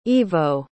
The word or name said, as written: Ivo